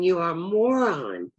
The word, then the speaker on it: Moran: You had asked me to research our family name, Moran. And it's pronounced moron.